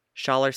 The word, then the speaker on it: Schaller: One of his coworkers named Schaller, I believe is how you pronounce it, S-C-H-A-L-L-E-R. We're going to say Schaller.